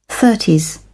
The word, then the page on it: thirties: British English